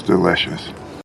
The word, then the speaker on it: delicious: It's delicious. – Es delicioso, or sabe delicioso. También tenemos la contracción con it's, it is. Y en la palabra delicious, vamos a subir la entonación en la segunda sílaba.